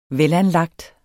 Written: velanlagt

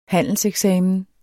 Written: handelseksamen

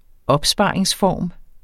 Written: opsparingsform

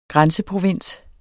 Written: grænseprovins

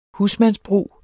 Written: husmandsbrug

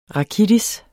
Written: rakitis

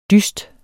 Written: dyst

